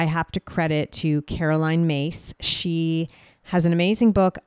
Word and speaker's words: Myss: Her last name is M-Y-S-S, but it's pronounced Mace.